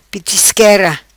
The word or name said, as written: Pitisquera